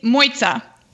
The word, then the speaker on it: Mojca: So it's Moitza. – Moitza, like pizza.